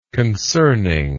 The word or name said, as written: concerning